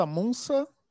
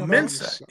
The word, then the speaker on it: Mensa: Mensa Munsa? – No, Mensa. No, Mensa.